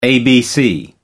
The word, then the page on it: abc: American English